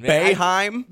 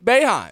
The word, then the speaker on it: Boeheim: And he's in, how is it pronounced? – Come on. – Boeheim. – Boeheim. – This thing Bay, like Bay. – Yeah. – And then Heim. – Boeheim. – There we go.